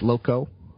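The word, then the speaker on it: Locu: So keep an eye on Lytro, Loku, and Ziosk. It's kiosk spelled with a Z. It's Loco, L-O-C-U, L-O-C-U, and Lytro.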